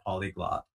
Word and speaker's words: polyglot: And when you say you're in the top 1% of all polyglots, I was actually pronouncing it incorrectly. I was pronouncing polygot, but it's polyglot, so I apologize for that.